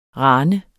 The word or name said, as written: rane